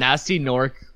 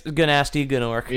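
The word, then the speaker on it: Gnasty Gnork: Nasty Nork, spelt with two silent G's. – Yeah, it's Ganasty. Oh, okay. It's Ganasty Gnork.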